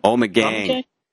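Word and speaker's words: Ommegang: Omegang.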